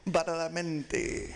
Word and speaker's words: Badalamenti: Badalamenti.